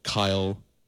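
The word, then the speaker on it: Cyle: It's pronounced like Kyle, of course. Kyle Lahren, the goal for Canada.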